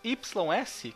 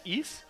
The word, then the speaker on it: Ys: O Akuma Jo do Drácula X, o R-Type, jogo de navinha, os RPGs famosos YS, que eu acho que se pronuncia Is, não é?